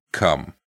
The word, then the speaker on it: Combe: So, a person was riding over Blagden Hill, returning from Taunton to Cumbe St Nicholas. It's spelt C-O-M-B-E, but I think it's pronounced cum or cum.